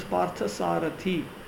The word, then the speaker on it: Parthasarathi: His name is Parthasarathi, long E, because he worked as charioteer of his friend Arjuna.